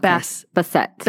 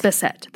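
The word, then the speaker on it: Bastet: So, and I'm going to explain exactly what the Basset cat is. – Like, how do you spell it? – It's B-A-S-T-E-T. – Okay. Basset. – Basset.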